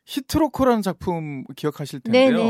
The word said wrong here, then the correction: 허트로커: 허트로커인지 갑자기 발음이죠.